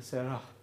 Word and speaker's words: Sarah: So it goes from Sarai, the Y sound at the end, to Sarah with the H sound at the end.